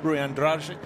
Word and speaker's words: Andrade: We just heard from Rui Andrade, as he's pronounced with a sort of Portuguese end to the name, from Angola.